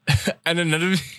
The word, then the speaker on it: Anunoby: Is that not how you pronounce it? – OG Anunoby, my God. Anunoby? – Yes. – That sounds weird. – Ananobi. – Ananobi? That's what I said. – Ananobi. – Okay. OG Ananobi.